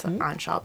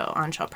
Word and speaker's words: Anchal: I will spell it A-N-C-H-A-L. It's Onshall Project. And then kind of the most we share is Instagram, so lots of stories. So Onshall Project.